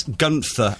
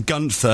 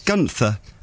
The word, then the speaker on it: Gunther: It's not Gunther, it's Gunther, as in Gunther, and then she puts a big duh in capitals.